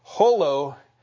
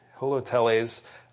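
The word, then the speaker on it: Holoteles (ὁλοτελεῖς): Holoteles. Holoteles. Whole, it sounds like that. And that is, I think that's where we get the word from. Holoteles, and a telos is a goal or an end or a finish or a purpose.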